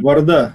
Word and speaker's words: Варда: Аньес Варда, французский язык. Ударение. – Варда. А вот я, кстати, не уверен, что именно так произносится ударение. Ты уверена прям? Да, да? Уверена.